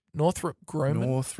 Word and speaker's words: Northrop Grumman: Northrop Grumman? – Northrop Grumman.